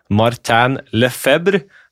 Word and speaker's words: Lefebvre: Steve Whitney har de også presentert, i tillegg til Martin Lefebvre. Er, det, er vi i nærheten der, eller? Jeg tror den franske uttalelsen der er eh, helt innafor.